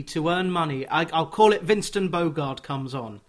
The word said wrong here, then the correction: Bogarde: Well, don't mention Bogarde. – Bogarde, sorry. You're quite right. The Dutch pronunciation is...